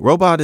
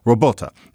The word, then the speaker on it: robot: Now, we can't know how they said it in the play, but if it's spelled R-O-B-O-T, well, if it's spelled that way and it's a new word, many people, of course, were going to say robot because it's like patriot, idiot, despot, zealot, robot. Perfectly natural.